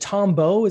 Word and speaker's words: Tombow: So where did, is it Tombow? Is that how I pronounce that? Mm-hmm. So yeah, how good. Did that come in during that whole augmented reality phase? Can you tell us what Tombow is?